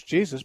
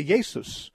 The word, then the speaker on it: Jesus: When you go to Germany, they say Jesus, which sounds like the Greek form, but it's spelled like the English Jesus. It's just the J is pronounced like a Y.